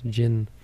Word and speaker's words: jinn: Or the jinn, I used to think it was, but they're pronounced jinn in Palestine, because our professor actually lived in the West Bank and studied the jinn stories, these stories of possession.